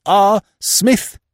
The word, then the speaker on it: Psmith: Address applications to R. Smith, the P is silent, Box 365.